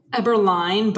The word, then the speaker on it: Eberlein: Eberlein, but honestly, no one ever gets it right, so I just answer to whatever.